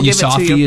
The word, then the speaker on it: Yousafzai: Yousafzai, is that how you say it? – Yes.